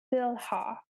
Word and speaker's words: Bilhah: Um, so I'm going to pronounce Bilhah. And um, this was, I believe, Rachel's maid. Yes, it was Rachel's maid. So that's kind of the pronunciation there.